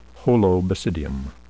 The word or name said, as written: holobasidium